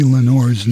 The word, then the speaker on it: Lenoir: And J.B. Lenore's name was spelled L-E-N-O-I-R. However, here on this recording, it's It was changed to Lenore, which is kind of phonetic pronunciation.